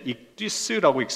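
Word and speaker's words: 익디스 (ἰχθύς): 이 물고기라고 하는 말은 헬라어로 다섯 글자로 되어 있는데 익디스라고 읽습니다. 익디스 이렇게 읽는데 이 다섯 개의 헬라어 하나하나가 각각 머리 글자를 의미합니다.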